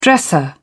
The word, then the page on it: dresser: British English